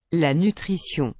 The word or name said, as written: la nutrition